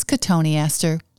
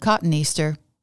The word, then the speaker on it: cotoneaster: Then there is cotoneaster, long O, often misspoken cotton easter.